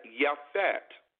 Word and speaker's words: Japheth: You talk about Shem, Ham, and Japheth. Okay, that's how you would pronounce it in the Abri or in the Hebrew. Shem, Ham, and Yafet.